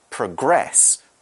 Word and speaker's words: progress: We can also use the verb spelled in the same way as a verb, in which case it's pronounced progress. Okay, it's a stress is on the end of the word, in which case it's the act of making progress or developing something towards the end.